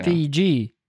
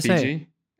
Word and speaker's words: Fiji: And in the way it's related to Fiji, it's specifically for travel ads, because here they pronounce it Fiji, and the syllable being stressed differently just really annoys me every time I hear it on the radio. – You know, Fiji. – So we say Fiji, and they say Fiji.